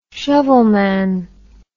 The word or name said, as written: shovelman